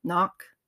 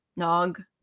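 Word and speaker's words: nÓg: And so that, the land of Tir na n'Ag is, or depending on where you are in the UK, it might be pronounced Nok, Nog, Tir na Thuin, Magmel, Avalon.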